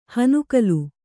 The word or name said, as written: ಹುನಗಲು